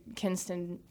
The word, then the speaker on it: Kinston: I mean, I remember when we went to Kinston for his decision.